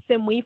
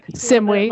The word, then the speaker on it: CIMWI: And so, and I'll call them SIMWE for short. – SIMWE. I wasn't sure how to pronounce it. CIMWI or SIMWE.